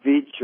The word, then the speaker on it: Veatch: Black and Veatch, or Black and Vetch, however you pronounce it, V-E-A-T-C-H, of Kansas City or St. Louis, anywhere there in the Midwest.